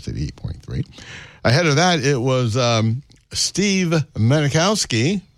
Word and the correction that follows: Mazikowski: I believe that's the way it's pronounced. Yeah. No, it's Mazikowski.